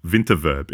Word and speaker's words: Winterverb: W in German is pronounced like a V in English, V. So Winterverb, it always sounds to me like it's the winter reverb.